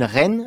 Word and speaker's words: Reyne: écrit par Régine Reine, alors ça s'écrit R-E-Y-N-E, qui fut durant 10 ans la présentatrice de nombreux grands music halls de Paris.